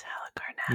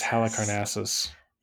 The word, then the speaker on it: Halicarnassus: That's Halicarnassus. – It's Halicarnassus. – And apparently, Halicarnassus.